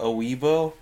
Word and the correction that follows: weeaboo: I was a weeaboo. – A weeaboo, I'm sorry. – It's pronounced weeaboo.